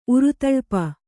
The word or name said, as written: ಉರುತಳ್ಪ